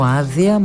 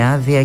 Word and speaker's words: άδεια: Έτσι σήμερα, λέει ο Νίκος Σαραντάκος, στη Νέα Ελληνική έχουμε το ουσιαστικό άδεια με τις πολλές σημασίες του και το θηλυκό επίθετο άδεια, δύο λέξεις ομόγραφες και περίπου ομόηχες. Περίπου διότι η πρώτη προφέρεται τρισύλλαβη και η δεύτερη δισύλλαβη, κάτι που εκμεταλλεύτηκε ο Μανώλης Ρασούλης στο στίχο του «Απόψε πήρε άδεια και με την τσέπη άδεια».